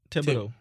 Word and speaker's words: Thibodeau: Oh, there's something I want to bring up with Minnesota and how Timmy Thibodeau, or how do you pronounce his name? Their coach. – Thibodeau.